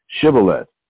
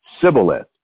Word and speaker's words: Shibboleth: Oh, then said they unto him, say Shibboleth. And he said Sibboleth. For okay. He could not... – Right. – For he could not frame to pronounce it right.